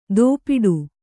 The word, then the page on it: ದೋಪಿಡು: dōpiḍu